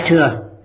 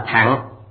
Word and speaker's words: thừa: Đại Thừa là tỷ dụ, chữ thừa này thời xưa đọc là thẳng, hiện nay mọi người chúng ta đều đọc là thừa, chúng tôi cũng đọc theo là thừa, theo âm xưa ngược lại không ai hiểu, khiến người nghe cảm thấy kỳ quái, cảm thấy không tự nhiên, phải không?